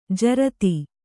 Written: ಝರತಿ

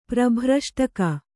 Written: ಪ್ರಭ್ರಷ್ಟಕ

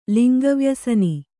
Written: ಲಿಂಗವ್ಯಸನಿ

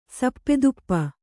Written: ಸಪ್ಪೆತುಪ್ಪ

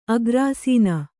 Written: ಅಗ್ರಾಸೀನೆ

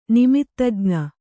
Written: ನಿಮಿತ್ತಜ್ಞ

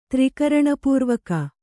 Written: ತ್ರಿಕರಣಪೂರ್ವಕ

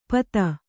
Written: ಪತ

